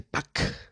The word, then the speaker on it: Puck: Eh, PUCK, creo que se dice Pac. Sí, creo que se dice así.